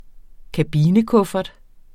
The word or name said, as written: kabinekuffert